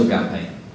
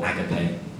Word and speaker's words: agape: And lastly, we come to agape, or agape. I'm probably going to keep changing how I pronounce it through this entire talk. I haven't decided which one I prefer.